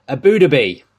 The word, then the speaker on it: Abu Dhabi: Abu Dhabi.